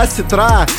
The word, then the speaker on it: осетра: Кстати, не осетра, а осетра.